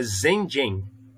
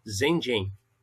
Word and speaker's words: Shenzhen: Ah, não, aqui é Shenzhen. É Shenzhen. O ZG é J. Então é Zhenzhen. O S é Z e o Z é J. Zhenzhen.